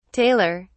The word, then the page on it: taylor: British English